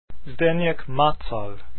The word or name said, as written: macal